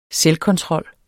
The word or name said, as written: selvkontrol